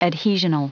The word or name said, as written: adhesional